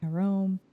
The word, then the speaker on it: Jerome: Jerome... That's not how you say it in French. It's like Jerome.